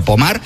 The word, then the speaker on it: Pommard: Se escribe Pommard, como si fuera en castellano, pero Pommard, pues allí en Francia, hablamos de unas imágenes.